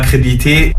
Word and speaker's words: crédibilité: Non, alors tu as oublié une syllabe, tu as oublié le lit. – Crédibilité. – Débilité, c'est comme débilité. Tu retiens, c'est comme débilité. Crédibilité, débilité, c'est pareil. N'oublie pas le lit, c'est important.